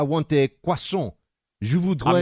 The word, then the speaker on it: croissant: When you go into any kind of a bakery and you want to order that crescent-shaped, very buttery pastry that the French call a croissant. Americans say croissant.